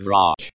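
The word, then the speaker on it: vrach (врач): Pronounced vrach. It is a masculine noun, and the ending, che, makes it sound like the English, ch, in, church.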